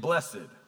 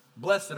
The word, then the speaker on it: blessed: but we're used to hearing it blessed, right? Blessed are the poor in spirit. Blessed are those who mourn. Blessed are the peacemakers. There's nothing wrong with pronouncing the word that way because it means the same thing, whether you say blessed or blessed.